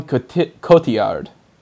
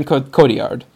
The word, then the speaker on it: Cotillard: The real standout, though, is totally, and I'm horrible at this name, because I always want to say it my way, but I know it's not pronounced that way. Marion Cotillard. Marion Cotillard.